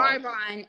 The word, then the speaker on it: Arbonne: Argon, spell it for me. – Arbonne. A-R-B-O-N-N-E. Arbonne. – That's A-R-B-O-N-N-E for the viewers.